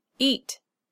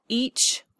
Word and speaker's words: eat: Eat is one syllable with a stop consonant, not each or eachy.